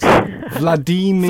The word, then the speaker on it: Vladimir: But he also says Vladimir rhymes with redeemer. So it's Vladimir.